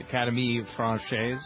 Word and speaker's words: Académie Française: Académie Française? – Oui.